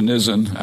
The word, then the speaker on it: Nisan: That's how it's pronounced by Jews, Nisan, actually.